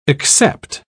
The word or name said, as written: accept